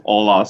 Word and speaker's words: Ola: But, you know, as many people would find it much easier to pronounce Ola. So, yeah, Ola, that is.